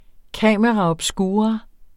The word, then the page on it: camera obscura: ˈkæˀməʁɑ ʌbˈsguːʁɑ; kaməʁɑ ʌbˈsguːʁɑ